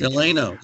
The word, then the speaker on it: Delano: I appreciate you for correcting without pointing it out explicitly that I was saying the name incorrectly. The Road to Delano. Thank you. – Delano, yes.